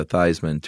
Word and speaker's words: Theismann: The very famous story of quarterback Joe Theismann, who changed his spelling or his pronunciation to Theismann to rhyme with Heisman.